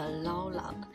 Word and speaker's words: Laula: 那这样的一个歌名大概是念作 Laula， L-A-U-L-A 是乐趣的意思。